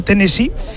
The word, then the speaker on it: Tennessee: porque dicen los lugareños que esto no se pronuncia Tennessee, como decimos todos, se dice Tennessee,